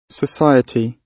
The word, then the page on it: society: British English